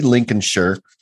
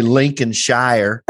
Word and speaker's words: Lincolnshire: Ian Huntley was born in Grimsby, Lincolnshire, because that's how they say it. I would say Lincolnshire. – Me too. – Lincolnshire.